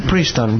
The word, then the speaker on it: Princeton: ¿Cómo has dicho que se pronuncia? – Princeton, vale.